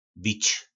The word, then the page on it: bic: po nasimu